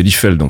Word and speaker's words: Liefeld: Je voulais juste corriger par rapport à l'auteur, enfin au dessinateur de Deadpool, c'est Liefeld en fait, hein, que ça se prononce pas Liefeld.